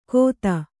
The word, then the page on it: ಗೋಧ: kannada; gōdha